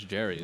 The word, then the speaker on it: Jairus: No, it's not gyrating. It's Jarius.